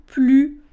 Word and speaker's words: plus: Donc, on ne prononce pas le S de plus dans deux situations. Première situation, la négation. Ne plus. Il ne fume plus de cigarettes. Il ne mange plus de bonbons. La négation, on ne prononce pas le S et on ne prononce pas non plus le S quand on a un adjectif derrière plus. Donc il est plus sympathique, il est plus gentil, il est plus merveilleux.